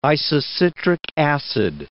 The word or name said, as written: isocitric acid